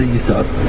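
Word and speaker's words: Yisoschar (יששכר): So I think there's a Rashi in Devar Yomim that says that the name Yisoschar is a Kriya Ksiv. You're only supposed to pronounce one of the shins. And he didn't follow that Rashi. Others disagree. He always pronounced the Yisoschar.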